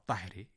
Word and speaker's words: طاہرہ: آپ نے توجہ کی ہوگی کہ ان کا تلفظ فارسی میں رضیے, مرضیے اور طاہرے کیا گیا ہے, یعنی چھوٹی ہے کو جو قاعدہ ہم نے آپ کو بتایا ہے اس کے تحت بڑیے میں تبدیل کر دیا گیا ہے.